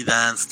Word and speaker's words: DNCE: Bueno, se pronuncia Dance, pero se escribe D-N-C-E, ¿no? – Ah, sí, Dance, tienes razón.